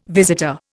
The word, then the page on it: visitor: Indian